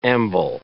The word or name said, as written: amble